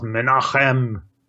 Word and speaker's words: Menahem: I think this is pronounced Menachem.